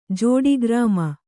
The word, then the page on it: ಜೋಡಿಗ್ರಾಮ: jōḍi grāma